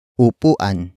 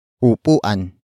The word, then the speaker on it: upuan: That object is upuan. Upuan. Upuan. Upuan is spelled as U-P-U-A-N.